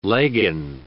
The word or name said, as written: leggin